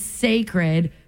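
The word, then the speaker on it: Cécred: She's calling it Sacred, pronounced, it's like sacred, like the word sacred, but it's actually spelled C-E-C-R-E-D. It's a play on the last syllable of her word, Beyonce.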